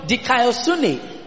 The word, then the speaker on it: dikaiosune: This is from the Greek word dikaiosune. This is from the Greek word dikaiosune, which is spelled D-I-K-A-I-O-S-U-N-E. Which is spelled D-I-K-A-I-O-S-U-N-E.